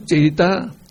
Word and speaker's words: citta: Citta, E is pronounced A, no, citta.